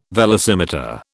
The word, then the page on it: velocimeter: British English